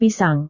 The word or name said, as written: pisang